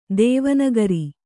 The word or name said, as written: ಧ್ವನಿಕಾರ